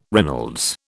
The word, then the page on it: Reynolds: British English